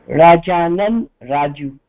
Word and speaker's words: राजानन् राजु: अकारं योजयामः ओके बहु सम्यक् राजानन् वा रजनन् कथं उच्चारणं करणीयं भवतः नाम राजानन् राजु